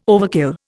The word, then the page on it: overkill: Indian